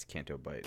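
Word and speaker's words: Canto Bight: Yeah, it's Canto Bite.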